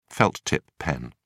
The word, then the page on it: felt-tip pen: British English